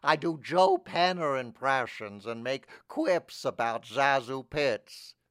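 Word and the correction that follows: ZaSu: Sezu. – What? – Her name is pronounced Sezu Pitts.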